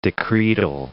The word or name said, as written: decretal